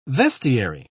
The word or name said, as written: vestiary